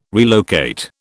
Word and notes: relocate: British English

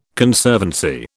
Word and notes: Conservancy: British English

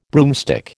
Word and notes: broomstick: American English

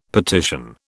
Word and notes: petition: British English

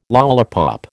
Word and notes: lollipop: American English

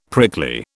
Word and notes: prickly: British English

